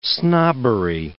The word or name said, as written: snobbery